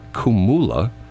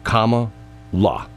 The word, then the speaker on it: Kamala: It's not Kamala. It's not Kumula. It's not Carmela. The pronunciation is comma, like the mark you use when you're writing, la. So think of comma, you know, like if you say like period, comma, comma, la. That's the pronunciation, the punctuation mark and a la after it.